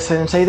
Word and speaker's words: sensei: De que al malo malote, como ve que le está ganando, su sensei, ¿lo he dicho bien?